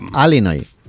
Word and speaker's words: Illinois: Se pronuncia Illinois. – Sí.